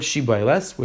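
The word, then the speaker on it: shibboleth: So they would ask them, pronounce the word Shibayles, which the people from Ephraim were not able to pronounce the Shin, they would always say as a sin.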